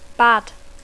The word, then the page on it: bad: English